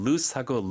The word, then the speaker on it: loose: 가끔 loose하고 lose, 잃어버리다 헷갈릴 수 있거든요. – 네. – 그러니까 loose, 헐렁헐렁한 거는 O가 두 개 있고 네. – S 발음이 S 발음이에요.